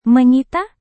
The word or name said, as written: menyita